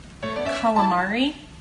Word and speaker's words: calamari: Is it calamari or Gallimard? – Calamari. – Calamari. – Calamari. – A lot of people don't use the vowels. They say Gallimard instead of calamari.